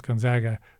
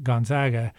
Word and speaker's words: Gonzaga: Most of the world pronounce Gonzaga, and they're right, but we pronounce it Gonzaga.